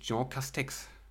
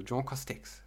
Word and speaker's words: Jean Castex: natürlich, ähm, da sagen die tatsächlich Jean Castex. Also Jean Castex. – Ach so. Also wie, ja, okay. man es halt auch so aussprechen würde. – Ja. Castex, gut.